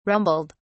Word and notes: rumbled: British English

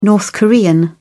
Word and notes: north korean: British English

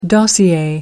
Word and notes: dossier: American English

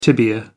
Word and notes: tibia: American English

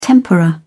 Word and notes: tempera: British English

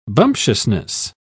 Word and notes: bumptiousness: British English

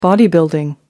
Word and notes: bodybuilding: American English

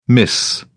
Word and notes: mis: British English

